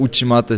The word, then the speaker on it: Uchimata: Dort stand auch das Uchimata, dein Lieblingstechnik ist, ich habe es falsch ausgesprochen, oder? – Lala, das war schon richtig so.